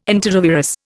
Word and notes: enterovirus: Indian

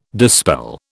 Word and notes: dispel: British English

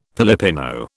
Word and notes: Pilipino: British English

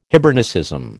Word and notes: Hibernicism: American English

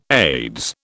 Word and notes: Aids: British English

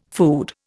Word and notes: food: Indian

